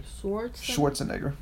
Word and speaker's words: Schwarzenegger: How do you say his name? – Schwarzenegger.